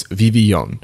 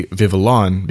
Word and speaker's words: Vivillon: It's spelled V-I-V-I-L-L-O-N. And I'm not going to say Vivillon because that obviously doesn't even sound right, but Vivillon with a French influence, that's the way I'm going to pronounce it.